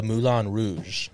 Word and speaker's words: Moulin Rouge: Becomes infatuated with Satine, a singer of the Moulin Rouge.